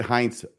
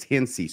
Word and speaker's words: Hinsey: I believe I said Heinz last Friday. I've been corrected since then by people who are apparently in the ward and stake. And I guess it's Hinsey, so I want to try and pronounce it correctly.